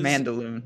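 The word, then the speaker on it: Mandaloun: Mandaloon. – Yeah. Dilly, dilly, dilly. Yep. Mandaloon. – Here I am trying to pronounce it like it is en français, but apparently it is not.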